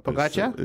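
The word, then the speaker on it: Pogačar: What's it called? – Pogacar. – Pogacar is the correct pronunciation.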